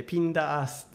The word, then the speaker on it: 闘ヤギ: 闘ヤギと書いてピンダーアースって言うんですよ戦うヤギ、はい、ヤギ戦うんだよなあれね。